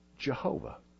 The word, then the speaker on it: Jehovah: They took the consonants, Y-H-W-H, and they inserted into them the vowels for in Adonai. And we got Yehovah. More commonly, we pronounce it Jehovah.